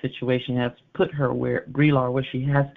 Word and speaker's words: Brillar: but I think her name is Brelar. – Brelar. – Brelar, all right.